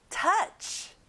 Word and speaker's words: touch: 만지다, touch. 친구들, 터치가 아니라 아 소리하고 끝에 ch 발음을 내보세요. Touch.